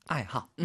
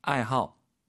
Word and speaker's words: 爱好: 爱好, 취미죠. 뒤에 그 하오 그 발음, 호자의 발음이 하오가 아니고 허, 사성으로 한다라는 거 좋아하다.